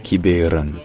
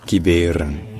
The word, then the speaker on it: Kiberen: Parce qu'en fait à l'origine, en breton, la ville s'appelle Kiberen. Kiberen. Donc non seulement le E au milieu est prononcé, non seulement il est prononcé E, mais en plus c'est là qu'il est l'accent tonique.